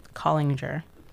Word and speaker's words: Kallinger: So it's nope. K-A-L-L-I-N-G-E-R. Collinger. – K-A? – K-A-L-L-I-N-G-E-R. – Okay. – The way you were pronouncing it, I thought it was a C-O.